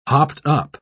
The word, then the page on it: hopped-up: British English